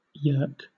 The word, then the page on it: yerk: British English